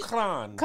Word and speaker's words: shukran: The way we shukran.